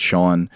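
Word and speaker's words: Sjón: It is about a speaking event with an Icelandic novelist who goes by the name Sean, spelled S-J-O-N, but pronounced Sean.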